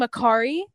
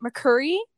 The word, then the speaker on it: Mercari: And she was mispronouncing Mercari. She was calling it Mercuri.